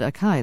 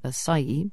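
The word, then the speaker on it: açaí: And You pronounce it acai. That's very interesting. A-C-A-I, which a lot of people say acai, but you say acai.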